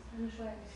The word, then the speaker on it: feng shui: How do you pronounce it? Feng Shui? Is that how you pronounce it? That's the Chinese thing. F-E-N-G? – Feng Shui. – Feng Shui?